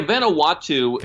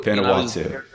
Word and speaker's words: Vanuatu: Vanuatu or something? – Yeah, Vanuatu. – Vanuatu.